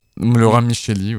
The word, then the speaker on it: Laurent Micheli: De Laurent Micheli, on prononce. – Laurent Micheli, oui, ouais.